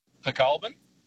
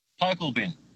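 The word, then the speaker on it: Pokolbin: Well, what is the name, the place name that you find funny when it's mispronounced? – Pecolbin. – Pecolbin? – Yes. – Oh, yeah. – Just out of Singleton. – Yes. And what do you often hear? – Pokelbin.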